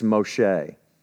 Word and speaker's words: Moshe: So instead of Moses, it's Moshe.